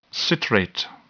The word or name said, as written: citrate